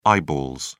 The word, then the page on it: eyeballs: British English